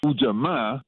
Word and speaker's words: Ujamaa: Ujamaa, that's the proper pronunciation because the double A at the end of words in Kiswahili means that that's where the accent goes.